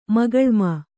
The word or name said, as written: ಮುಖಲೋಮಿ